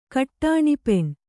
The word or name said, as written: ಕಟ್ಟಾಣಿಪೆಣ್